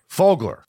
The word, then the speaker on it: Fogler: Okay, Fogler, I said it right.